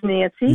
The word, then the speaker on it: Nancy: Hello, my name is Nancy.